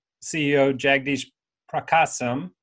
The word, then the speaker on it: Jagdeesh Prakasam: And number two is actually the new number one at Rotella with CEO Jagdish Prakasam. Did I pronounce that right? – Oh, that's perfect. – Who we just called Jag when we talked to him.